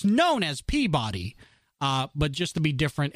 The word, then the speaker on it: Peabody: And there's a town in the northeast called Peabody. But apparently the pronunciation and the emphasis on the syllable is incorrect. It's actually Peabody.